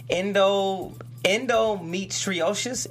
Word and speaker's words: endometriosis: It's called endometriosis.